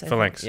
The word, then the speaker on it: Phalanx: Phalanx? – Phalanx, yeah.